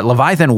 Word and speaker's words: Leviathan: Oh, Leviathan.